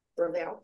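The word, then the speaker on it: Brevail: One is a flax lignin supplement called Breveil. So it's spelled B as in boy, R as in Roger, E as in Edward, V as in Victor, A as in apple, I, L, Breveil.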